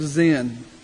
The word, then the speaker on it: Sin: God led the people to the appropriately named wilderness of Zin. It's spelled S-I-N. It's pronounced Z-E-N.